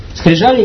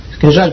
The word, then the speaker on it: скрижали: Скрижали или скрежали? Скрижали, по-моему.